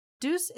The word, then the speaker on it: DEUX: DEUX is pronounced like the English word deuce, D-E-U-C-E, but it's spelt like the French word for two, D-E-U-X, which made it a fitting name for the duo.